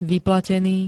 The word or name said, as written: vyplatený